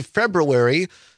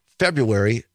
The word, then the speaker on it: February: Or is it February with the R in it? I most Wisconsin say February. The R is silent. But I think grammatically speaking, February is the right way to say it.